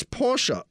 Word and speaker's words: Porsche: Willie, in German, it's Porsche. If Ferdinand was French, the E was not pronounced.